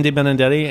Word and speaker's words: De Benedetti: I'm Horst Dornbusch, and it's spelled D-O-R-N-B-U-S-C-H. – And we got, who else is here? – I'm Christian De Benedetti, and somebody pronounced that Christian De Billy Billy today.